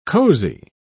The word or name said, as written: cozy